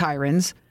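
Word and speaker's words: Heirens: My mother and father told me, you mispronounced the killer's name. It's Herons. The point is, it's not Herons, it's Hirons.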